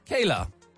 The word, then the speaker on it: KLA: Vocal, vocal, spelled K-L-A, but pronounced Kayla.